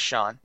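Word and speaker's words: Chone: We had a question from Northside Sox asking how you pronounce Sean Figgins' name, and it is Sean.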